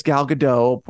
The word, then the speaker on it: Gal Gadot: Gal good I, don't I don't know how to pronounce Gal Gadot. Okay, Gal Gadot, Gal Gadot. Apparently they say it's Gal Gadot.